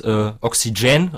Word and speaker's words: Oxygen: Sauerstoff wird Oxygen ausgesprochen, mit Betonung auf der letzten Silbe.